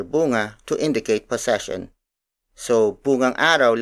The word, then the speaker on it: bungang araw: For example, bungang araw, bungang araw. Bungang araw. Bungang araw is spelled as B-U-N-G-A-N-G hyphen A-R-A-W.